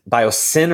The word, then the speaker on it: Biosyn: Biosyn or Sys? – Biosyn. But it's spelled with Biosyn. Y, so you don't notice that it's a sin.